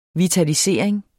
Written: vitalisering

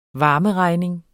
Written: varmeregning

